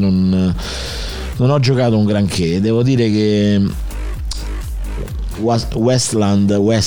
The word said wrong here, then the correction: Wasteland: come si dice? Come cazzo si pronuncia? – Wasteland.